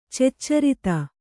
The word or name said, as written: ಚೆಚ್ಚರಿತ